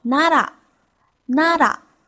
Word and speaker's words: 奈良: 拉来源于良心的良字，日本呢有一个古都叫做奈良，日文就读作 nara，nara。大家还记得吗？